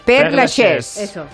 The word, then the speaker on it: Père Lachaise: Se llamaba eh, Père Lachaise.